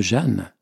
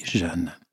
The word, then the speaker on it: Jehan: Moi, quand j'ai vu la manière aussi dont le nom était écrit par ma collègue, je prononce Jeanne, mais il s'écrit J-E-H-A-N. Jeanne, mais on le dit Jeanne.